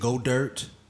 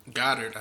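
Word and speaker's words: Goedert: Was it Go Dirt? Is that how you say it? – Goddard. I think it's Goddard. Yeah.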